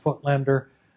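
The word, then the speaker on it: Voigtländer: That's how that's pronounced, Foetlender.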